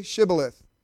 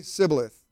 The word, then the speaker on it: Shibboleth: And if he said no, then they would say to him, then say Shibboleth. And he would say Sibboleth, for he could not pronounce, we can barely do it, for he could not pronounce it right.